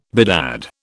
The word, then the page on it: bedad: British English